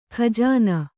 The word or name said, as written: ಗಜನಿ